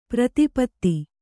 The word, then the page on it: ಪ್ರತಿಪತ್ತು: prati pattu